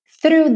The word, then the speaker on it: through: Então, olha, through, lembrando uma palavra que aparece com alguma frequência, o GH. Pode fingir que não está lá, porque não vai ter pronúncia. E só lembrando de começar com o som do ffff, linguinha lá nos dentes da frente, porque a gente tem TH. E volta com essa língua já fazendo R.